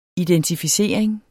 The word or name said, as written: identificering